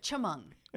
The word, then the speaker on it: Chemung: I remember coming in, and I did not know how to say the town, Chemung. I said it, Chemung. Chemung, Illinois. Chemung.